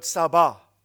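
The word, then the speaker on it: sabah (צבא): The word hosts here is the Hebrew word sabah, T-S-A-B-A. The T is silent, sabah in Hebrew.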